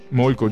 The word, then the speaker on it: Molko: Molko, giusto? – Sì, sì, sì. – Chissà come si scrive Molko. M-O-L-K-O.